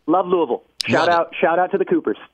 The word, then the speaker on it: Louisville: Much love to Louisville, or as they pronounce it out there, Louisville.